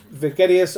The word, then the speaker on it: Vegetius: And Vegetius has, which I think actually is the better pronunciation, has divides his book into four chapters, and the last two, which are the let me try to explain this more clearly.